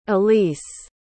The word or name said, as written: allyse